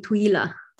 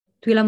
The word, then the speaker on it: Twila: Say your name for me in your Twila. Italian accent. – Well, in Italy, they call me Twila. – Oh, Twila Muzzi? – Yeah. – Which? – Yeah. Twila. Twila Muzzi.